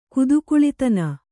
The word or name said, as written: ಕುದುಕುಳಿತನ